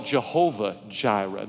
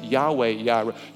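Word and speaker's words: Yahweh Yireh (יהוה יראה): We call it Jehovah Jireh. The Hebrew language doesn't pronounce the J's. Yahweh Yireh, Jehovah Jireh, the Lord will provide.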